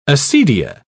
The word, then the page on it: acedia: British English